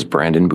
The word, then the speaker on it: Brandon: My name is Brandon Boudreau. B-R-A-N-D-O-N. Boudreau is B-O-U-D-R-E-A-U-X.